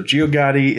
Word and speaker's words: Geogaddi: Geogaddi. Am I pronouncing that right? – That's how I would say it. I think that's how most musical scholars would pronounce it. – So Geogaddi is the second studio album by the Scottish electronic music duo Boards of Canada.